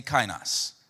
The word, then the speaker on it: kainos: That word is new, is pronounced kainos. Say kainos. Kainos, this is new of a different kind.